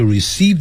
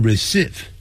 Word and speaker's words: receive: receive your letter 不要念成 receive Receive 啊，是拉长的音长元音